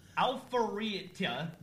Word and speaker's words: Alpharetta: Is Alpharetta, Georgia. – Alpharetta, Georgia.